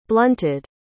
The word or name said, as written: blunted